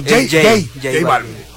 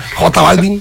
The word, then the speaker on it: J Balvin: Jill Balvin, así se pronuncia Jill Balvin. – Jay. – Yo prefiero preguntar para luego regalarla con otro. Jay Balvin. Ok, pues es que nomás dice una J, pues, o sea.